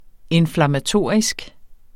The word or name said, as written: inflammatorisk